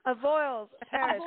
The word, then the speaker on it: Avoyelles: That's the only thing I've pronounced correctly today, because we went down to Avoyelles Parish. Arlene, help me. – Avoyelles. – Avoyelles Parish. And apparently, I still can't do it.